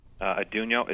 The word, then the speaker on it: Atunyote: Adonijo. Mm-hmm. How do you spell it? – Adonijo is spelled A-T-U-N-Y-O-T-E.